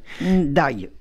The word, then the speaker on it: Ndiaye: Ndiaye. C'est dur à dire, Sibeth Ndiaye. Ndiaye. Comme vous voulez. Vous le prononcez à la, à la sénégalaise. Marie Ndiaye, exige qu'on dise son nom comme ça, c'est le même.